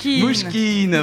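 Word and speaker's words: Mnouchkine: Mouchkine, voilà.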